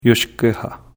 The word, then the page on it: Iouskeha: Wendat